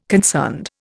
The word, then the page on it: concerned: Indian